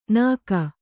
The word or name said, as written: ನಾಗ